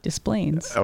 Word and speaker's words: Des Plaines: How do you pronounce D-E-S-P-L-A-I-N-E-S? – Displains.